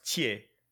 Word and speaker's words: Thiers: It was called like La Place Thiers, Thiers. It's pronounced Thiers.